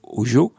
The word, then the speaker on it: hozho: Alors ça s'écrit H-O-Z-H-O, mm-hmm. on le prononce Ojo.